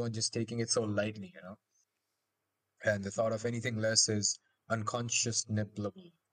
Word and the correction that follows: unconscionable: or something like that. – Unconscionable. – Unconscionable. I can say it properly.